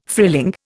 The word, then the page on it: frilling: Indian